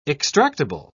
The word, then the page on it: extractable: British English